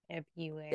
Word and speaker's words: February: They do pronounce the second R. – February. – There it is. Yeah. February. So it's just an Australian thing.